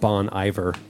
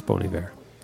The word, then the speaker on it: Bon Iver: I'm seeing people tweet right now. Is that really how you pronounce it? Bon Iver? – Really? – Just, yeah, they have no idea. They've been saying it wrong for a couple years now.